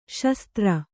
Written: ಸುಸ್ಥಿರ

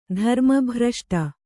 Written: ಧರ್ಮಭ್ರಷ್ಟ

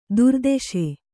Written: ದುರ್ದೆಶೆ

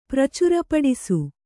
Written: ಪ್ರಚುರಪಡಿಸು

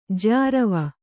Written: ಜ್ಯಾರಾವ

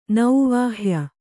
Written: ನೌವಾಹ್ಯ

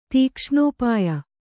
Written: ತೀಕ್ಷ್ಣೋಪಾಯ

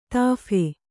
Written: ತೋಫು

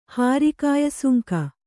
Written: ಹಾರಿಕಾಯಸುಂಕ

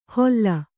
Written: ಹೊಲ್ಲ

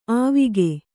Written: ಆವಿಗೆ

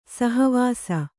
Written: ಸಹವಾಸಿ

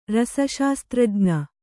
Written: ರಸಶಾಸ್ತ್ರಜ್ಞ

